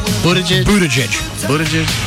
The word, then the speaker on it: Buttigieg: Buttigieg? – Buttigieg.